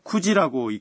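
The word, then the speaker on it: 九時: 네, 9 뒤에 지가 있으면 쿠지라고 읽습니다.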